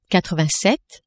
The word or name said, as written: quatre-vingt-sept